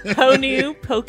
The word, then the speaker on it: Honu Poke: Honu Poke.